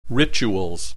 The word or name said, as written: rituals